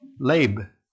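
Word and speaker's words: Leb (לב): You know, the heart, in Hebrew, it's pronounced Leb, L-E-B, with a little house over the roof over the E.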